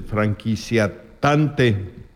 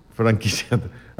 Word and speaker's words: franquiciatario: Él es el franquiciatante. – Franquiciatario.